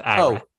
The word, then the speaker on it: Ira: It's pronounced Ira.